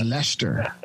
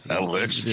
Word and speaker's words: Leicester: I think it's just Leicester. I think it's just Leicester. – Leicester.